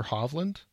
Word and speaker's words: Hovland: Viktor Hovland.